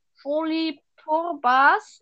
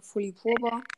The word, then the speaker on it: Folipurba: Ja, so wie du es ausgesprochen hast, einfach Folipurba. Und dass es dann auch äh, halt deinem äh, Anchor-Podcast ist und ja.